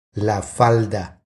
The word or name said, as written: La falda